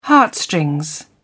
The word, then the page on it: heartstrings: British English